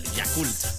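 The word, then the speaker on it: Yakult: Ah, no, espérame, no, lo pronuncié mal. – ¿Cómo? – O sea, es Yakul.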